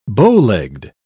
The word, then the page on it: bowlegged: British English